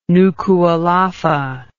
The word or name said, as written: Nukualofa